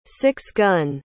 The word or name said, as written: six-gun